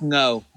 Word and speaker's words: Ngo: It's Ngo. G kind of silent.